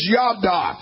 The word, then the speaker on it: yada: Now, I think this word probably would be properly pronounced as Yada.